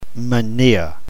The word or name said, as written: mania